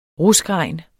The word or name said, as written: ruskregn